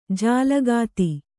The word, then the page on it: ಜಾಲಗಾತಿ: kannada